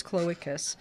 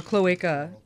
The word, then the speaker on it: cloaca: Well, the word clocus, we mispronounce it. The actual word is cloacus. – Or cloaca.